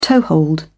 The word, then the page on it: toehold: British English